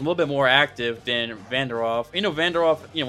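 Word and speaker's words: Vanderaa: That's how you say his name, right? Vandera.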